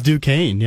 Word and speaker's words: Duquesne: It is Duquesne, yeah.